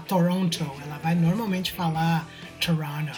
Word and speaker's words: Toronto: Tipo, o jeito que as pessoas aqui da cidade, né, o canadense médio, principalmente quem mora em Toronto mesmo, se refere à cidade, em termos de pronúncia e tal, é Toronto. Então so, esse esse segundo T ali praticamente nem existe.